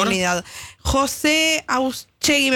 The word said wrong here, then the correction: Otasegui: me parece el apellido, no lo quiero pronunciar mal en este momento, pero es uno de los directivos de la unidad penal número 31, José Otasegui. – Otasegui.